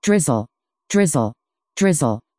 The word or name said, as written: drizzle